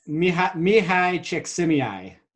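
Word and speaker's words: Mihaly Csikszentmihalyi: Mihai Csikszentmihalyi.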